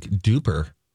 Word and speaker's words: DUPR: It says duper. It's spelled D-U-P-R, Donna. – Durup. – I want that to be D-U-R-P.